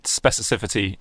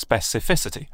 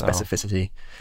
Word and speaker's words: specificity: I said specificity in a video time, and didn't even realize I was saying it wrong because it's specificity. – It is specificity.